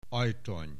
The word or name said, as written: Ajtony